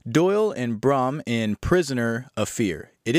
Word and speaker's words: Braham: I've seen Braham, Brahim, Braham, and I say Braham. So it's got a little hum there at the end.